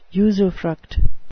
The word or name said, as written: usufruct